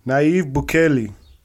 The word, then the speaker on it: Nayib Bukele: Nayib Bukele. – Yep. – Did I say that right? – Maybe. No, you did.